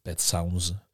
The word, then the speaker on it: Pet Sounds: Et c'est sur le making of de l'album Pet Sound. Donc Pet Sound qui est le Pet Sounds, il y a un S, il faudrait que je le prononce,